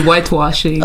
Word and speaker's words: whitewashing: Ça s'approche, oui, whitewashing. – Ok, whitewashing.